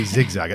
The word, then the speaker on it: xigxag: And we should say xigxag at this point, by the way.